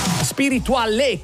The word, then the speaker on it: Spirituale: Spirituale con l'accento sulla E, il pezzo di Biagio Antonacci con il featuring di Saif.